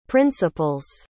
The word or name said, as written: principles